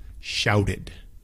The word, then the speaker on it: shouted: Next one is shout, S-H-O-U-T, gritar, shout, shout. 5, 4, 3, 2, 1, shouted, shouted. This one is two separate syllables. It has the ED, so it's S-H-O-U-T-E-D, and it's shouted, shouted.